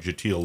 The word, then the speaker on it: Kjetil: If you see his name on the marquee anywhere, check out Jatil Lund, which is spelled. – Yes, I was just going to mention that. KJETIL, and I take it the K is silent.